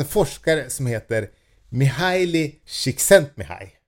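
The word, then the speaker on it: Csíkszentmihályi: Ja, men det uttalas tydligen Csikszentmihalyi.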